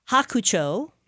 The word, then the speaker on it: Hakucho: But also they are opening up a brand new Japanese-inspired restaurant, Hakucho.